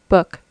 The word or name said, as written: book